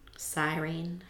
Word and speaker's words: Cyrene: Another theory comes from, I don't know how to say this, but it's a Greek colony of Cyrene. – Spell it. – C-Y-R-E-N-E. – Cyrene.